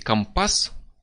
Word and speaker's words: компас: И он будет, как моряки говорить, компас, с ударением на «а»,